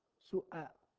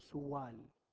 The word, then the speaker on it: سؤال: سؤال، كلمة سؤال مكونة من 4 أحرف، السين، لا ما في واو، همزة، شكل بس، شكل الواو، لكن هي حقيقة همزة، والصوت أن تنطقها الصوت همزة، تقول إيش؟ سؤال، ما أنت بتقول سوال بالواو، سؤال، واضح؟